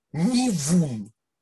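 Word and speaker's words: нивун (נוון): Пишется тоже как «нун». «Нун», «вав», «нун». Но читается оно уже как «в» нивун, то есть атрофия.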